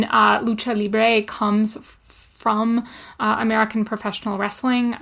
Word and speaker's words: Lucha Libre: And I'd like you to tell us a little bit about Lucha Libre.